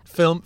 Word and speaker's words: film: Film production. There's not two syllables in film.